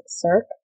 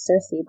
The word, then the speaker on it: Circe: And if you have been pronouncing this wrong as Cirque the entire time, along with me, I'm right there with you. I pronounced it Cirque for a really long time. I actually pronounced it Cirque until I finished the book. And then I found that it was Circe by, like, looking it up.